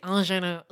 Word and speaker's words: ingenue: And you know the Academy loves a, how do you say this word? I-N-G-E-N-U-E. – Ingenue. – Okay.